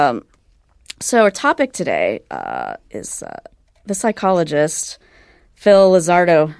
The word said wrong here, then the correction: Zimbardo: Am I saying that right? – Zimbardo. – Zimbardo. Yeah. I had the Z in the wrong place.